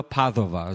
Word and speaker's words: Padova: And so the Scrivenelli, you can look this up, but Giotto in Padova, P-A-D-U-A, Padua, Padova is the way they pronounce it.